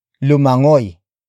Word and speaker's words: lumangoy: The next word is lumangoy. Lumangoy. Lumangoy. Lumangoy is spelled as L-U-M-A-N-G-O-Y.